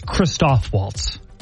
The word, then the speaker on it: Christoph Waltz: I believe it's Christoph Waltz. Christopher Waltz. – Oh, Christoph. – Christoph.